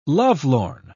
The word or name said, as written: lovelorn